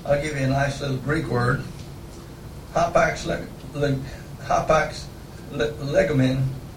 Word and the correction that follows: legomenon: How do I pronounce that, Baron? Legomenon. I put the emphasis on the wrong syllable every now and then.